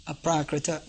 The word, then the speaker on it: aprakrita: Just like you'll find the word aprakrita is a, short a, so you pronounce it a. Then pr, then a, there's long a. So it becomes apra, not apra, apra. Then k, ri, there's not long ri, it's short, so. And then short a at the end, so it becomes aprakrita, not aprakrita.